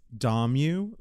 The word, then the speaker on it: Dommu: It's Dommu, like I'm going to Dommu tonight because you didn't know how to pronounce my name.